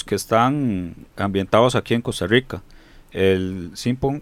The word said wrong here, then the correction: Siphon Filter: Siphon Filter, el que vos habías dicho.